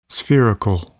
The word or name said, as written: spherical